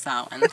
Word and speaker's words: Samhain: Yeah, I mean, it's pronounced Samhain.